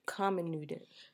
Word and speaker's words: comminuted: Are you saying it right? – Comminuted. – Comminuted.